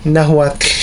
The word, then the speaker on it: Nahuatl: With Nahuatl, the T and the L has a similar sound, but the L has like a sh sound at the end, and it's almost silent. So I think I got it right. It's pronounced Nahuatl.